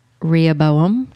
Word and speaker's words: Rehoboam: Rehoboam. Did I say that right? – Mm-hmm.